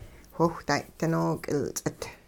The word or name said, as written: whiwhday dinook'ilts'it